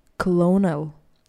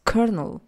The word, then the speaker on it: colonel: Diz aqui, quando olhas para esta palavra, pensas provavelmente na pronúncia clonal. E quem te pode culpar? Contudo, não é assim tão simples, pois pronuncia-se Colonel.